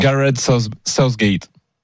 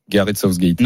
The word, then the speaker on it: Southgate: Gareth Southgate. – Non. – Gareth Southgate. Non, je pourrais pas. Gareth Southgate. – Mets ta langue entre les dents, t'as pas compris ça.